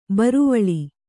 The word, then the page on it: ಬಿರುವಳೆ: Kannada